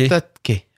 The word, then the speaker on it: Astatke: Astatke. Astatke. Faut pas oublier le T avant le K.